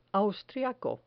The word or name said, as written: austriaco